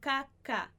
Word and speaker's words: cacahuetes: O H não vai ser pronunciado, então cacahuetes que sirven en la.